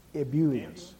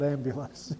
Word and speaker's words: ebullience: Ebullience. – Ebullience. Not ambulance. Ebullience. Yes.